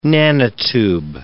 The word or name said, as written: nanotube